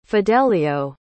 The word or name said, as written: fidelio